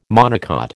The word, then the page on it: monocot: American English